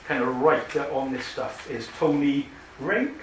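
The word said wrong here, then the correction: Reinke: How do you say his name? Rinke.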